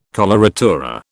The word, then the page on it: coloratura: British English